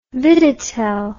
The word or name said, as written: viditel